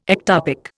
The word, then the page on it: ectopic: Indian